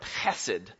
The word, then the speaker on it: hesed: This is one of those beautiful Hebrew words that you kind of pronounce in the back of your throat, hesed.